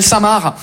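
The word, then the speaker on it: Saint Marc: On est d'accord les Français que Samar, ça s'écrit M-A-R-C, comme le prénom Marc. Mais vous, vous mettez un sein devant le prénom Marc. Le C, il est parti en grève.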